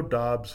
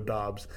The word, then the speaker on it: Doubs: It's Romeo Doubs here. Worthy buzz, and that's how you say his name, Doubs versus Dubs. Whatever you call it, Doubs, Dubs.